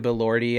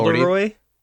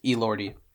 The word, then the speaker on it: Elordi: It's Jacob not Elderoy. – No, it's Elordi.